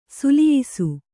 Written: ಸುಲಿಯಿಸು

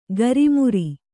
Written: ಗರಿಮುರಿ

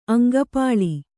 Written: ಅಂಗಪಾಳಿ

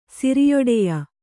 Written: ಸಿರಿಯೊಡೆಯ

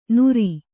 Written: ನುರಿ

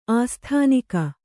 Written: ಆಸ್ಥಾನಿಕ

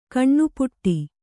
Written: ಘನಪಾಠ

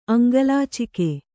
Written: ಅಂಗಲಾಚಿಕೆ

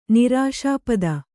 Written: ನಿರಾಶಾಪದ

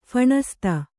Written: ಫಣಸ್ತ

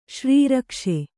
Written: ಶ್ರೀರಕ್ಷೆ

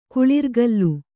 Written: ಕುಳಿರ್ಗಲ್

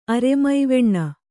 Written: ಅರೆಮೈವೆಣ್ಣ

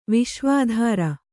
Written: ವಿಶ್ವಾಧಾರ

